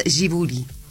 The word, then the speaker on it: Ghibli: Dijimos, sábado 23 a las 21 horas en el Teatro Plaza, y todo lo que tiene que ver con el Ghibli Sinfónico. Sí, yo estuve buscando la pronunciación ideal, digamos, la que es justamente japonesa, es Ghibli. Debería ser así.